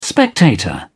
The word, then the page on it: spectator: British English